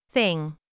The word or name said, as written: thing